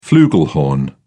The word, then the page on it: flugelhorn: British English